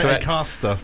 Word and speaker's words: Acaster: I was going to say Acaster. – That's how you say it, mate. Yeah, it's like exactly how you just said it.